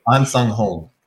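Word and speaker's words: Ahn Sahng-hong: It's Ahnsong Hong. – So Ahnsong Hong. There's a Hong. – Yeah. – Ahnsong Hong. – Okay. Ahnsong Hong.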